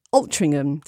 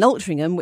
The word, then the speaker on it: Altrincham: I'm currently living in Altrincham. Did I say that right? – Yes, you did, yeah. – Thank you. I'm currently living in Altrincham, which is about 30 to 40 minutes away.